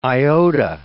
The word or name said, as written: iota